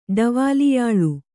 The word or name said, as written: ಡವಾಲಿಯಾಳು